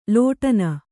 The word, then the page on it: ಲಾಟನ: lāṭana